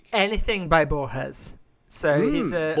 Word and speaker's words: Borges: Come on, it's Borges. Say it right.